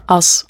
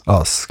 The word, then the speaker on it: Us: Und momentan veröffentlichst du auf dem Zürcher Label Us, oder wie spricht man das aus? – Us. – Us, genau.